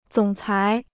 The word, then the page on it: 总裁: zǒng cái